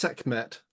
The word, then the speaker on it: Sekhmet: I'm going to suggest that's pronounced Sekmet. It sounds segment.